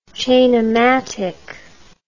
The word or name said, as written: chainomatic